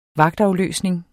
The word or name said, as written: vagtafløsning